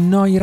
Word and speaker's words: Neu: Sulle frequenze di Noi Radio, neuradio.it. Si scrive Neu pronuncia noi e vuol dire nuova.